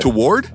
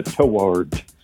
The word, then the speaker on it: toward: Toward? – Uh-huh. – What's the third syllable? – You almost turned it into toward. – How did you just say it like it's T-W-A-R-D, like sword, toward? – T-O-R-D.